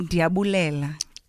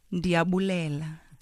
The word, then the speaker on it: Ndiyabulela: Inkosi, you can say Ndiyabulela. – Say it one more time. I'm going to say it right. – Ndiyabulela. – Ndiyabulela. – That means I thank you.